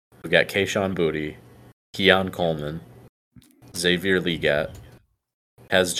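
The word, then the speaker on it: Boutte: I asked Kayshon Booty to clarify the pronunciation of his last name. He confirms it's Booty.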